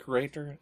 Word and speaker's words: curator: He's a museum creator, how do you say that, curator? – Curator.